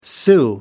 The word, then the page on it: sou: American English